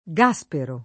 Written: Gaspero

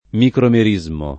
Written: micromerismo

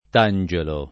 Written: tangelo

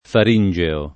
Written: faringeo

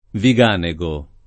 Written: Viganego

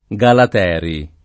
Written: Galateri

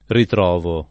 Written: ritrovare

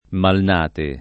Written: Malnate

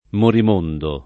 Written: Morimondo

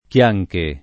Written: Chianche

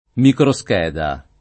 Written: microscheda

